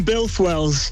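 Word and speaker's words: Builth Wells: Is it Beef Wells? Put me out of my misery. – We're in Bilth Wells.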